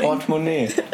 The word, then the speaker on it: Portemonnaie: Seit wann betont man die erste Silbe? Portemonnaie, das ist französisch.